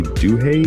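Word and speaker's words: Duhé: It was Weathen and Ellie Duhay, D-U-H-E, accent on there.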